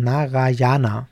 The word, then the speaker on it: Narayana: Alle anderen Gottheiten sind Naranya und Naraya, wie habe ich es gerade ganz falsch ausgesprochen? Narayana, Narayana, Entschuldigung, ähm, korrigiert mich rückwirkend auf das, was ich gerade gesagt habe.